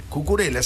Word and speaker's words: Cucurella: Cucurella, sí, lo dije bien.